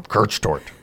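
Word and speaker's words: Kirschtorte: You got to say Kirchtort. You got to say it like that. Kirchtort. It is a word that is perhaps beyond the reach of many people who have not studied German.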